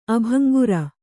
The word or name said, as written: ಅಭಂಗುರ